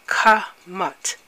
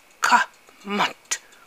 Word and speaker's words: Kmt: We call the continent Kahmat. It's spelled K-M-T. That's right, K-M-T. And the pronunciation, if you're going to spell it like you pronounce, you will be K-A-H-M-A-T. Kahmat. Kahmat.